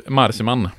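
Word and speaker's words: Marcimain: Mikael Marsimain, vad sa vi att den skulle uttalas? – Marsimain. Det är som Malou von Sivers uttalade det efter 10.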